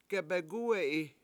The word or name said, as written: gebegUwe7i